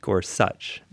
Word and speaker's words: Gorsuch: And by the way, listening to the confirmation hearings, I learned his name is pronounced Gorsuch.